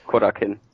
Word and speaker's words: Korakuen: Wenn ich mich nicht ganz täusche, äh, sogar in der berühmten Korakuen Hall, wenn ich das richtig ausspreche. – Korakin.